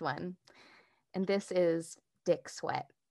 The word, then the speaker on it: Swett: S-W-E-T-T, but pronounced Sweat like S-W-E-A-T.